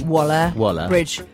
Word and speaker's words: Waller-Bridge: Waller-Bridge. – Bridge. – I want to say Bridgeta. – I can see why it's so confusing. I mean, there's a news reader, Neil, and a trained broadcaster, Phoebe Waller-Bridge.